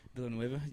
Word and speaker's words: Villeneuve: Dennis Villeneuve, is that how you say it? – Yeah. – Villeneuve, yeah, he's one of my favorite directors.